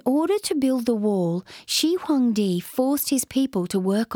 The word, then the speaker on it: Shi Huangdi: The first of the Great Walls in the north of China was built in the Qin, pronounced Qin, Dynasty by the Emperor Shi Huangdi, pronounced Shi Huangdi, in about 200 BC.